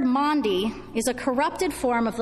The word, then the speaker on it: Maundy: Words like Passover or Pentecost or Maundy, as in Maundy Thursday. Often pronounced Monday Thursday by the younger ones in our congregation, this word and the meaning behind it have always fascinated me.